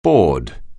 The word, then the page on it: bored: anglais